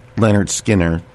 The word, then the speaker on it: Lynyrd Skynyrd: And then we did a live version of Tuesday's Gone, which was originally on the Lynyrd Skynyrd album, pronounced Lynyrd Skynyrd, and that was their debut album.